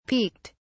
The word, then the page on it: peeked: British English